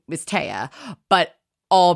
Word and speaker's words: Tea: Her name is, in fact, Thea. No H. – Right, spelled T-E-A, but not pronounced T, obviously, pronounced Taya.